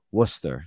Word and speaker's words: Worcester: It is Worcester.